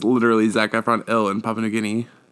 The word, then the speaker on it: Papua New Guinea: Papua New Guinea.